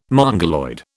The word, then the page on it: Mongoloid: British English